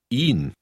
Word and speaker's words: ihn: Ihn. Ihn. – Note that in word 13, the H is not pronounced. Instead, the H lengthens the E sound.